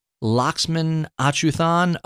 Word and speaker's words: Lakshman Achuthan: Welcome to the show, Lakshman Achuthan of the Economic Cycle Research Institute, better known as ECRI.